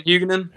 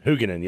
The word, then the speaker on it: Huguenin: as well as the I'm going to butcher his name, so I'll let you pronounce it, the senior for me. Begins with an H. – Barrington Huguenin? – Huguenin, yeah. Those